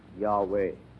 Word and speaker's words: Yahweh: The Jews had a name for God, which scholars tell us today should be pronounced Yahweh.